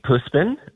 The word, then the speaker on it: Puspin: Puspin? Puspin? How do you say that? – It's a Sweet Puspin. It means a Filipino street cat.